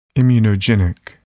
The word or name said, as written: immunogenic